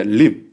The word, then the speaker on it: limb: 用他非常僵硬的 limb, 注意鼻不发音的，鼻不发音的啊，鼻在 M 后面是不发音的，